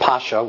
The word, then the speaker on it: Pascha: We see that Jesus observed the Passover, and the Greek word is Pascha, sometimes pronounced Pascha, with his disciples.